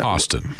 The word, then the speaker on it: Hostin: Hostin.